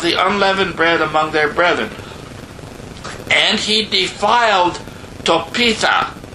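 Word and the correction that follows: Tophet: Did I say that right? Topitha? Topeth,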